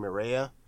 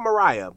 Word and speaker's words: Maria: I think it's like Maria. It's not Mariah, but it, it sounds like Mariah.